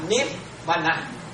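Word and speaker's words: Nibbāna: Còn nếu mà tiếng Bali á thì cái đó là Nip Vana.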